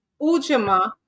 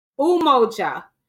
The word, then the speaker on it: Umoja: Help me pronounce that Ujamaa Mag. Umoja.